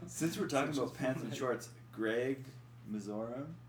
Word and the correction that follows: Mirzoyan: Yeah, how do you say his last name? – Mirzoyan. – Mizorian? – Mirzoyan. – Mirzoyan. – Yeah.